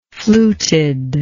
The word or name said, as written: fluted